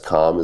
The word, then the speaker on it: Comm: And yes, Comm is my real last name. It's spelled C-O-M-M.